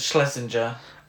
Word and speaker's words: Schlesinger: Directed by John Schlesinger. I believe it's pronounced Schlesinger.